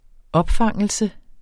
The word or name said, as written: opfangelse